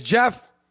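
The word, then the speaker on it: Geoff: My name's Jeff, spelled G-E-O-F-F. I don't know why there's an O in my name, but I learned about silent letters really early in life, all right?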